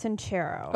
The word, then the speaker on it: Sincero: Jen Cicero? – It's pronounced Sincero.